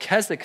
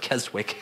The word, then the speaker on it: Keswick: There is a school of thought on that, and it's called Keswick theology. It's spelled with this weird W in the middle. It looks like Keswick.